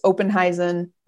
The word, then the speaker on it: Oppenhuizen: So Oppen, which is a play on my last name to help folks pronounce it right. It's Oppenhuizen. There's a second P in a silent U, and it throws folks for a loop.